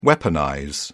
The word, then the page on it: weaponize: British English